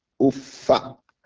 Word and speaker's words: uff: fala taqul lahuma uffin. Uff. You can also say uffa, aw uffi, aw uff. It has various ways that it is pronounced.